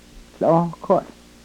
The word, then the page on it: hlohkohl: yurok